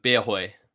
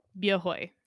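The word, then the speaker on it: Bia Hơi: Bia Hoi. – Bia Hoi.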